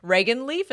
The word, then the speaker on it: Reginleif: Regan Leaf, is that it? – Yeah. R-E-G-I-N-L-E-I-F. – Regan Leaf.